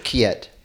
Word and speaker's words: Quillette: Kiet. Yeah, well, it's a French word, so Kiet.